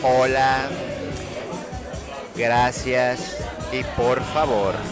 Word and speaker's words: por favor: It's very important, the R. Por favor. Por favor. I mean, you have to over say it, but it's roll the R's, babe.